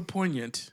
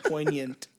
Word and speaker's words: poignant: So poignant is. Did I say it wrong that time? – No, that was right on. That was right on. It was just very well articulated. – Yeah, poignant.